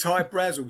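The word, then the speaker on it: Hy-Brasil: But it's High Brazil because that's how the Irish would pronounce it, because it's Okay.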